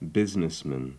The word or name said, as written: businessman